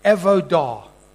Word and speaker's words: avodah: That word, work, in the Hebrew, is the same word, I'll spell it to you, it's called avodah. A-V-O-D-A-H.